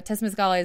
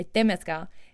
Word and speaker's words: Temazcal: It is a Temazcal is a, sorry, I'm saying that wrong. A Temazcal